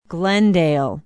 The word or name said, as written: glendale